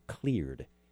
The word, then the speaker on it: Kleard: So I'm so pleased to have here today, Jonathan Martis, and he is the CEO and co founder of a company called Kleard. Now, it sounds like cleared, like you cleared your throat, right, Jonathan? – Yeah. Yeah. – But it's spelled K L E A R D.